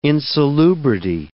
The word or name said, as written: insalubrity